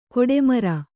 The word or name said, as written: ಗಡಿಮೀರು